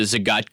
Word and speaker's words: Zagat: You've got the new Zagat Guide. Am I pronouncing that correctly? – You're pronouncing that correctly. It's the Zagat Guide and the Michelin Guide.